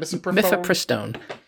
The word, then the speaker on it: Mifepristone: Anyway, they got on the misoprostone. How do you say that? – Mifepristone. – Mifepristone.